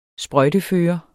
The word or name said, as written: sprøjtefører